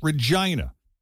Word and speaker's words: Regina: It's spelled Regina, but you pronounce it Regina.